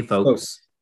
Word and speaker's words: folks: Yeah, I don't pronounce the L. Folks. – Don't pronounce the L and everything will turn out fine. Just say folks.